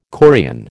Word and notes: chorion: American English

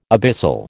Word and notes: abyssal: American English

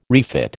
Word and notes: refit: American English

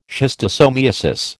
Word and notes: schistosomiasis: American English

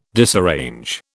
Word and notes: disarrange: British English

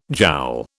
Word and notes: joule: British English

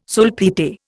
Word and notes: sulphite: Indian

